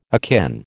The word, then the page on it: akin: American English